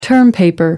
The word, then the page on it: term paper: American English